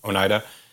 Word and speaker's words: Oneida: Oneida.